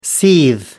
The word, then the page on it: seethe: American English